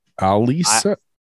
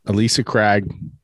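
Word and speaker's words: Ailsa Craig: How do you say it? Alisa. A I L S A. – Alisa Craig. – Alisa Craig, which is an island off the coast of Scotland.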